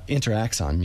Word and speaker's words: Interaxon: Now, is that this is the Intrazon Muse? I'm trying to pronounce that right. Is that Interaxon Muse? – Interaxon Muse.